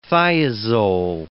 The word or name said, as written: thiazole